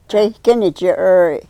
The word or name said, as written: cheykeni chyrry